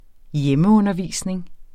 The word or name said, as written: hjemmeundervisning